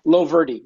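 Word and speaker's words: Loverde: I said it wrong. I know I did. Leverdale? – Loverdy, yeah. – Loverdy. Ah, close enough. Yeah. Guys, just punch it. It's L-O-V-E-R-D-E.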